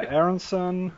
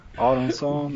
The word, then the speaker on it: Aronsson: Yeah, that was a new one, but yeah, Aronsson, Aronsson, that's a Swedish pronunciation.